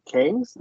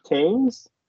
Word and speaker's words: Kang: So this movie was made to introduce all the different Kangs. Did I say that right? – Yes. – Kangs? – Well done.